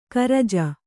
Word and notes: ಕರಜ: karaja

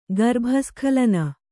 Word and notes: ಗರ್ಭಸ್ಖಲನ: Kannada

